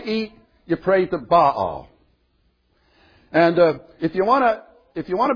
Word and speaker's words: Baal: Baal, they sometimes call him Baal, but it's really two syllables.